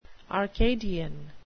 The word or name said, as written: arcadian